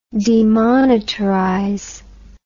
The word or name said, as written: demonetarize